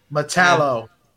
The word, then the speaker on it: Metallo: No, so they find Metallico's body, remains. I apologize if that's the right way to say it. – Metallo. – Metallo. Why? How did I say it? – Metallico.